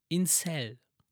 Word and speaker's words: Incel: Sagt dir ähm, der Begriff Inselbewegung, Incel? Wie schreibt man das? I-N-C-E-L. Wie eine Zelle, ne? Insel.